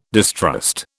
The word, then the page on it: distrust: British English